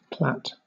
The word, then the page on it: plait: British English